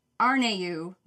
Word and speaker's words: Harnetiaux: It's Trish Arneau, which is spelled H-A-R-N-E-T-I-A-U-X. I'm very sorry if that's not how you pronounce it. That is what the internet says. But it seems like a big fake out because you're not saying a lot of the letters.